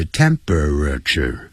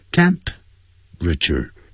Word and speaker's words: temperature: 这本来是四个音节 temperature，但实际老外念成三个音节 temperature，这样。